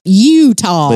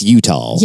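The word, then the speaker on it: Eutaw: We don't spell it the right way. It's E-U-T-A-W. – Yep. Utah. – But it's Utah.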